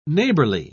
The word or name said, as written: neighborly